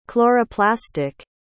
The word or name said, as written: chloroplastic